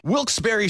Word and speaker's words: Wilkes-Barre: Wilkes-Barre Scranton's 97.9X.